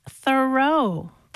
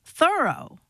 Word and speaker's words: Thoreau: All this time, I thought his name was pronounced Thoreau, but no. – How is it pronounced then? – Thoreau. – Like I thoroughly did it.